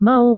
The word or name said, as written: mau